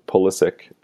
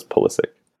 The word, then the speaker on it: Pulisic: Wanted to, yeah, no Pulisic, or Pulisic, as I guess technically he wants to be called. – I don't know. – So for all the Europeans who think Americans are pronouncing his name wrong, technically we are, but I guess he wants it pronounced as Pulisic.